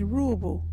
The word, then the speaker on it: ruubu: Our local Wadjuri word is Ruubu.